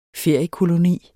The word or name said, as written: feriekoloni